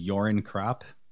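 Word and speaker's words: Göran Kropp: In that book, there's a character, he's a Swedish mountaineer, and his name is Jorin Kropp. – Okay. – I think I'm pronouncing that correctly. It's spelled with a G.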